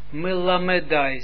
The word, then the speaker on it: melamedai: dat dit een mobiele schwa is. Dat betekent dat dit toch iets wordt uitgesproken. Ja, iets, een beetje klank, een beetje wat we noemen dan stomme e. Na mem, komt een soort stomme me, stomme e. Ja? Kijk, was melamedai, zie je? Melamedai.